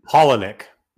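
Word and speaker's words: Palahniuk: Polonick.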